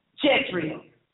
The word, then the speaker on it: Jezreel: Ahab rode and went to Jezreel. Is that the right pronunciation? Yes, ma'am.